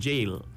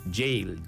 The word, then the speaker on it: gaol: Narisha Pandit says, the word is pronounced jail. Yep, that's correct. The name is pronounced jail. G-A-O-L is pronounced jail. It's a British way of saying it.